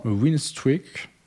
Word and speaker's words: Winstreak: C'est une appli qui s'appelle, alors attention à mon accent, Winstreak, W-I-N, S-T-R-E-A-K. Ok. Je sais jamais comment le prononcer. – Bon, bah, c'est très bien dit.